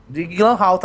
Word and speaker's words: Jake Gyllenhaal: Eu também não sei pronunciar o nome desse ator, caralho. Vamos lá. Ah, Jake. Jake Gyllenhaal? E, não, Jake Gyllenhaal. Jake Gyllenhaal tá certo.